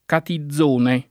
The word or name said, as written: Catizone